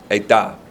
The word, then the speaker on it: edah: And that word synagoge is the equivalent in the Hebrew of the word kahal, which is spelled Q-A-H-A-L. But it's also the equivalent of the Hebrew word edah. Edah. E-D-A-H.